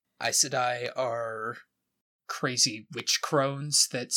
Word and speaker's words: Aes Sedai: So in the pronunciation guide, it is Aes Sedai.